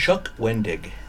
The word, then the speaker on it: Chuck Wendig: Chuck Wendig.